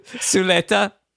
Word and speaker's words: Zulueta: So here we go from 1979 Spanish film written and directed by Iván Zulueta. Is that right? – You added one extra syllable, but we'll be good. – Zuleta. – There it is.